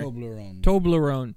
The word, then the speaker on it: Toblerone: Arizona, Toblerone. How do you say that? You said it earlier. – Toblerone.